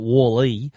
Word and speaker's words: Walle: Now, the original Wally, and that's spelt Wall, W-A-L-L-E, so that's how I'm pronouncing it, Wally, was a mounting system for the iPad.